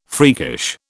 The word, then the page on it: freakish: British English